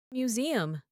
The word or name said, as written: museum